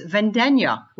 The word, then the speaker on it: Vendegna: But then I started studying Italian and I learned that actually it's Vendenya, like lasagna.